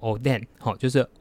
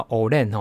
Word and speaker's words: Oden: 那讲到关东煮这个词呢，其实它的日文呢发音应该叫做 Oden，好，就是 O D E N。可是你有发现，哎，其实台湾人都叫 Olen 哦，就是黑轮这样子，它的发音比较像是 O L E N。